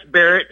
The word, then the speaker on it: Barrett: It's Barrett. How you doing? – Oh, Barrett, like the 50 caliber.